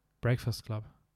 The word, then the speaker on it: Breakfast Club: Wie, wie sagst du Breakfast Club? – Breakfast Club. – Breakfast. Ja, okay, ja, sorry.